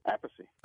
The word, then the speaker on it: Appice: How does Mom and Dad pronounce it? – Appice. – Okay, so that's the correct way.